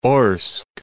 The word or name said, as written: Orsk